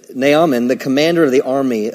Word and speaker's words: Naaman: Why do you say Naaman? Well, it's N A A M A N, so. Naaman. And by the way, I, Brother Copeland pronounces it that way.